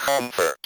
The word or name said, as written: comfort